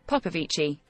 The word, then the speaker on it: Popovici: Nadia Popovic? Oh, how do you say this? Popovic. That's how I want to say it. – Popovici.